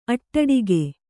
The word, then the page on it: ಅಡ್ಡುಗೆ: aḍḍuge